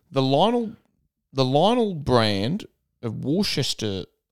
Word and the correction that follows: Worcestershire: Do you not know how to pronounce it? – How do you say it? It's Worcestershire. – Worcestershire. I said Worcestershire. – It's Worcestershire sauce.